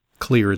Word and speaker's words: Xlear: So many of my patients, and yours truly, I'm using Clear. It's spelled, by the way, X-L-E-A-R.